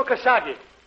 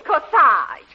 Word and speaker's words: corsage: Got you a beautiful Kasagi. – Kasagi? Oh, Parky, darling, it isn't Kasagi. It's Corsage. A-G-E is pronounced Arge, as in garage.